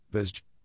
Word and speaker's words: Bazet: The random article for Tuesday, the 30th of April 2024 is Bazet. Bazet, French pronunciation, Bazet, is a commune in the Ardennes department in the Grand Est region of northern France.